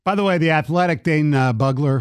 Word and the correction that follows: Brugler: who Bugler, I don't know how to pronounce it. – Bugler. – What? Brugler. I said Bugler. – Brugler. Yes, you did. – I know, Brugler.